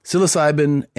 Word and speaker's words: psilocybin: I always forget how to say it. Is it psilocybin? – Psilocybin and ayahuasca, also separate from some of the other options.